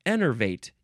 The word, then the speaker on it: enervate: The second form is pronounced differently. It is enervate. Yeah, this is the one, this is the way that I wanted to say it. Enervate. The first form, the adjective, is innervate. And the verb, this is a transitive verb, is enervate.